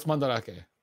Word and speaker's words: Mandarake: That how you pronounce that? I've been saying Mandrake all this time. – Oh, it's Mandarake.